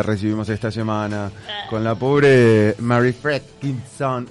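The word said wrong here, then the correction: Fredriksson: Todos lo pronunciamos diferente. Fredrickson. – Fredrickson. Es como dos pero Con Fred.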